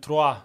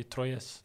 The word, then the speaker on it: Troyes: Ja, Troyes säger de ju, men i Europa och Sverige säger man ju Troyes.